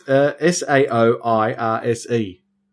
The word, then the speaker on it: Saoirse: It's S A O I R S E.